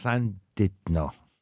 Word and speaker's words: Llandudno: So this is Llandudno. – Llandudno.